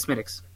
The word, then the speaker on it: Smithwick's: I've had an Irish guy make sure we didn't mispronounce it at an Irish pub. – Nice. – In town, but he was just like, oh yeah, no, it's totally Smithwick's.